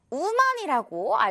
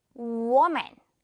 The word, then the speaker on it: woman: 많은 우리 한국인들이 우먼이라고 알고 있는 친구들 많죠? 우먼이 아니라 우리 W 발음 기억해야겠죠? 이거 워 발음 나잖아요. 그래서 woman 이렇게 발음이 나요. 워, woman, woman.